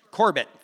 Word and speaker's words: Corbett: how do you pronounce his name? Colbert? Corbett, thank you.